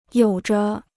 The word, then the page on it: 有着: yǒu zhe